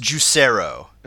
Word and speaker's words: Juicero: It's pronounced Juicero. – Juicero. – Juicero. I like your way better because it does, if you read it, like, as juice plus row, it is juice row.